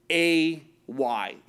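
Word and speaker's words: A-Y (εἰ): It's pronounced like A-Y. It's an A, a long A sound.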